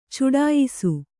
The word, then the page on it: ಚಡಾಯಿಸು: caḍāyisu